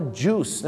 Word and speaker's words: juice: ไม่ใช่จุ้ยนะฮะต้องออกเสียงว่า juice